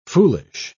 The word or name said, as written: foolish